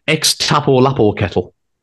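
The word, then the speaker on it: Xtapolapocetl: It is pronounced X Tapo Lapo Kettle.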